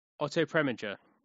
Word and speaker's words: Otto Preminger: Hurry Sundown, Otto Pregnant. I can never say his name properly. Otto Preminger. Thank you.